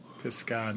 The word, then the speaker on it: Pisgah: So P-I-S-G-A-H. – Oh. – That's it. Pisgah National Forest.